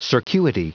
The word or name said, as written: circuity